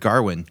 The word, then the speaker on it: Gawain: The latest from writer-director David Lowery, starring Dev Patel as Gawain, or as they pronounce it in this film, Gawain, right? Is that what you were hearing? Gawain? – I heard Garwin. – Yeah, I did too, but I think it's that like Welsh accent.